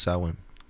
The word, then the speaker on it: Samhain: Samhain. No, it's pronounced Samhain.